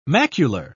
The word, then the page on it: macular: British English